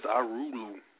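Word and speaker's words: Irulu: What the hell is Arulu? – A book? – An MP3 player. Video never heard of that shit before. – I guess that's how you say it. It's I-R-U-L-U.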